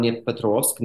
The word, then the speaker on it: Dnipropetrovsk: She came from Dnipropetrovsk, now Dnipro, which is the fourth largest city in Ukraine.